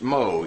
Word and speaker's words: Meaux: In 1521, Farrell went to a town in France, Meaux, M-E-A-U-X, and that's one of the reasons why I don't like French, because they don't spell the words like they pronounce them.